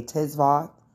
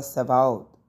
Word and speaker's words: Tzivaot: Some people pronounce the T and they say Tzivaot, right? I normally drop the T, making the T silent, and I say Yahuwah Sabaoth, okay?